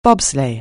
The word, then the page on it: bobsleigh: anglais